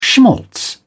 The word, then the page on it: schmaltz: British English